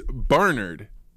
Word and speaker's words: Barnard: It's Barnard.